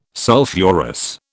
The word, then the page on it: sulphurous: British English